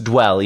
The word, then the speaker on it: Dvel: It's pronounced Dwell, even though it's spelled D-V-E-L. It's kind of, like you said, a play on the word dual.